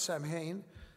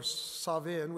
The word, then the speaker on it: Samhain: But according to the Encyclopedia Britannica, in ancient Britain and Ireland, the Celtic festival of Samhain, it's spelled S-A-M-H-A-I-N, sometimes you pronounce it Samhain, but it's basically pronounced Samhain or Savin, which means end of summer, was observed on October 31st.